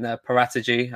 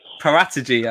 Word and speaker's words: Paratici: We've had a new sporting director in Paratici. Apparently, that's the right way to say it now.